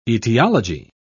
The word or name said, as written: etiology